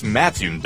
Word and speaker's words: Mattoon: It's Mattoon, dummy.